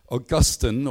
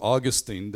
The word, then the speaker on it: Augustine: Augustine, or Augustine, depending on your pronunciation and your theological background, became one of the most influential leaders of the early church in the 4th century AD.